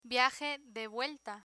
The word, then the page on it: viaje de vuelta: Spanish